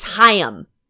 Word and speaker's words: Haim: And then it's like, the other two sisters are in the background, and they're like, Haim. Haim. – Yeah. – I might and watch me saying it wrong right now, but you get my point. – But that's going to be easy for me to remember now because, I mean, I know Lahayim.